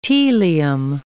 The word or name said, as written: telium